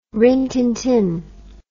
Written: Rin Tin Tin

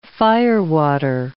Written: firewater

